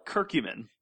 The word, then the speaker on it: curcumin: I call it curcumin.